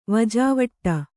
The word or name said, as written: ವಜಾವಟ್ಟ